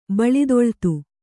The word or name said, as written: ಬಳಿದೊಳ್ತು